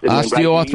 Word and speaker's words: osteoarthritis: The knee is, but I probably am not pronouncing it correctly, but osteoarthritis. – Osteoarthritis.